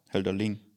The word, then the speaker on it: Hölderlin: Hölderling? Hölderlin? – Es ist mit einer kurzem I, also nicht mit langem. Schreibt man es. – Hölderling.